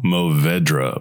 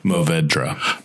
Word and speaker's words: Mourvèdre: Besides one of my favorite blends, it is a Grenache Syrah Mourvèdre blend. And so this one is 50% Shiraz, 40% Grenache, and 10% Morvan. How do you say it? Mourvèdre. Mourvèdre. I'm not French.